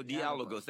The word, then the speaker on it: Dialogos: Michael Nevardakis, how do I pronounce the name of your radio show? – The Greek way is Dialogos. – Oh, there we go. Dialogos.